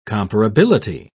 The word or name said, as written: comparability